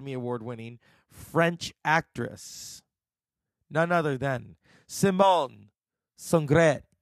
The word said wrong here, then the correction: Signoret: Simone Signoret.